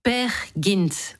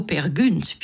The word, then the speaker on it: Peer Gynt: Peer Gynt, ou plutôt Peer Gynt, puisqu'en norvégien, le Y se prononce U.